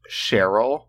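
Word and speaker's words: Cherylle: Also, her name is pronounced Cheryl. It's spelled Cherry, L-L-E.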